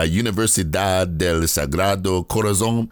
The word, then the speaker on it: Universidad del Sagrado Corazón: You attended La Universidad del Sagrado Corazón. Is that correct? Did I say it right, yes. – Doctor? – Yes.